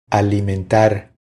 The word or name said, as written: Alimentar